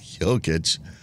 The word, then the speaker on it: Jokic: Jokic.